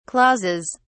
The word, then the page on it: clauses: British English